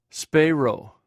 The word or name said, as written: speirw